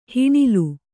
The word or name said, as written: ಹಣ್ಣೆಲೆ